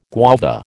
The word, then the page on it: guava: American English